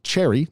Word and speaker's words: Cherre: New York-based Cherry, that's spelled C-H-E-R-R-E, as if irony didn't actually die, raised $16 million in a round that, according to VentureBeat, included both debt and equity capital.